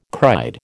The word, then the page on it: cried: American English